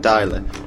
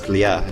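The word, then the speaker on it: Dlya (для): The people are calling them dialer. It's a kind of a misspelling or mistranslation or mispronunciation, whatever. The word's Dlya, it's D-L-Y-A, meaning for.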